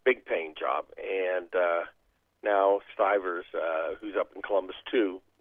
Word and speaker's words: Stivers: I guess he's, I guess that's how you say his name, Stivers, right? – Stivers. – Stivers, all right.